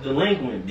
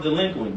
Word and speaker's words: DLEQUINT: Alright, but before y'all leave, man, can we get y'all to look in your camera and say, it's pronounced delinquent, bitch.